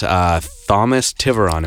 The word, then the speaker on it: Thomas Tiveron: We are joined by my guest, Thomas Tiveron. Am I saying that right? – That's 100% correct. It is Thomas Tiveron.